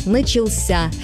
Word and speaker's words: начался: А вот в форме прошедшего времени мужского рода ударение уходит в конец – «начался».